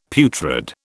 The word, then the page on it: putrid: British English